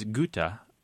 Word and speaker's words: gutta: words come from the same Latin word as the acronym that you gave us, the G-T-T-S. And the singular is gota, G-U-T-T-A.